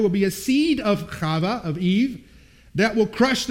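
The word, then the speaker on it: Chava (חוה): That's how we pronounce Eve. In Hebrew, it's Chava.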